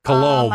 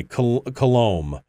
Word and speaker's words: Coulombe: Coulombe. – My husband, okay. It's French Canadian, so the B is not pronounced. – Okay, Coulombe.